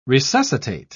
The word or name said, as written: resuscitate